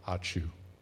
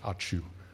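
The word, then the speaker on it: Ačiū: Allow me to say in the words of my people, Achiu, not the sneezing noise, A-C-I-U, and the C has a half circle, so it gets pronounced C-H, Achiu. A-C-I-U, which simply means thank you.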